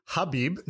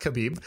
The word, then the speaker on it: Khabib: So it's actually Habib, not Khabib.